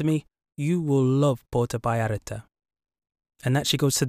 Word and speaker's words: Vallarta: When I first spoke to Paola, I said Puerto Vallarta because that's how it's spelt, with a V. But she told me how to pronounce the word properly, which is Vallarta.